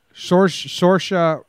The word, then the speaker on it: Saoirse: Saoirse Ronan. – Saoirse Ronan. – Saoirse like inertia.